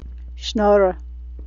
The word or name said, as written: shnorror